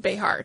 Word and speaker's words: Béjar: The original word, Béjar, being Spanish, was most likely pronounced Béjar, treating the X like a J to sound like ye, ye, or ha.